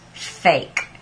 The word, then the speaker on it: PHEIC: I saw that someone on Telegram had sounded that out for themselves, and they said PHEIC would be pronounced fake.